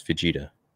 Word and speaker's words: Vegeta: The way he pronounced Vegeta. That's how you pronounce Vegeta.